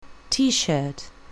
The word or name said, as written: t-shirt